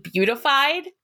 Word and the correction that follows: beatified: and the word is beatified.